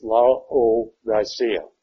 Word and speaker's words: Laodicea: So you pronounce, this is Latin, you pronounce the vowels independently. If there are two, you generally separate them. So it's Laodicea.